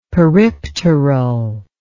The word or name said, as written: peripteral